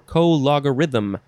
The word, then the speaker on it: co-logarithm: Next is an abbreviation, colog, and it is abbreviation for co-logarithm. But how do you say that word? I don't know. Let's read it, because it's our next word. Co-logarithm. You got to emphasize the law.